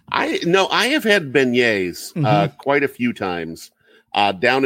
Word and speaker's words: beignet: The beignets.